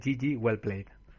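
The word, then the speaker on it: GG Well Played: Se dice Gigi Well Played.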